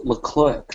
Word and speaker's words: Leclerc: But the dude himself, you know how Charles Leclerc pronounces it? – How does he say it? Does he say Charlie Leclerc? – He pronounces it Charles Leclerc.